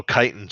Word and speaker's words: chitin: Oh, chitin cheeks. – And I'm probably just pronouncing it wrong. – Yeah, chitin is like the hard exoskeleton of an insect. – Yep. Oh, I knew what it was. I just apparently didn't know how to pronounce the word.